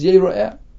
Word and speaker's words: Yeira'e: The way we read it is Yeira'e. But it's spelled Yud, Resh, Aleph, Hey, which can be learned as Yira'e, Hashem sees you.